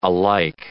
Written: alike

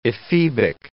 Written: ephebic

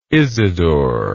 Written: Isidor

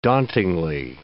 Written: dauntingly

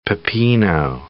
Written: pepino

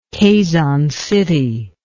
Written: Quezon City

